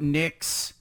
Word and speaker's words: Nix: Yeah, people, you know, we're talking about Nix rhyming with picks.